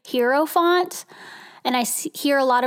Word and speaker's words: Hierophant: So I think, like, the way it can really be solidified in my own head, I think I'm going to just call it the Hierophant from now on. That seems to roll off my tongue a little bit better.